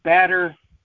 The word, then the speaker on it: spatterdock: Spatterdark? Spatterdark. I can't say it right. Spatterdock. Spatterdock. I was getting tongue-tied on that one.